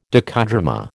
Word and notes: docudrama: American English

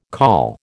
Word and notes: col: American English

